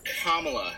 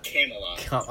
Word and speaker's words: Kamala: It is pronounced Kamala. Kamala. Harris, not Kamala.